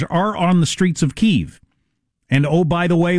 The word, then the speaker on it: Kyiv: Russians call it Kyiv. The country of Russia believing Ukraine belongs to us. Ukrainians call it Kyiv. So if the Ukrainians pronounce it Kyiv and only the invading army that is willing to kill them to subjugate them calls it Kyiv, I'm going with Kyiv.